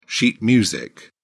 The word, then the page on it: sheet music: British English